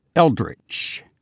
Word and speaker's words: eldritch: This time, it's a word pronounced eldritch, spelled E-L-D-R-I-T-C-H.